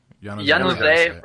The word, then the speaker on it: Januzaj: And how is it pronounced? Januzzi? – Januzzi. – Januzaj, right? Yeah.